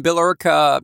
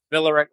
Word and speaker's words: Billerica: Yes, Billerica. Well, yeah, if you want to say it right, it's Billerica.